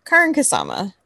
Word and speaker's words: Karyn Kusama: So, um, but yeah, so Karyn Kusama, which apparently is the correct pronunciation of her name.